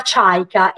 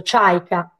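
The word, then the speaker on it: Chaika (Чайка): In russo, in russo si chiama Chaika ed ha già un suono diverso, Chaika.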